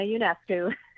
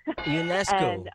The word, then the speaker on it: Ionescu: Well, first off, let me help you with the pronunciation, because it's a little bit of a tricky one, but it's Sabrina Ionescu. – Ionescu. – Ionescu, it's one of those last names that every time you say it, you get nervous right before you say it.